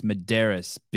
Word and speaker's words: Medeiros: Show the champ some fucking respect and pronounce his name right. You're always saying Medeiros. It's Medeiros, bitch.